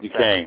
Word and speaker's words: Duquesne: I can't pronounce Duquesne. – That. – Duquesne.